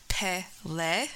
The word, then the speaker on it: Pele: Lighting up ancient Hawaiian legends, Pele, pronounced Pele.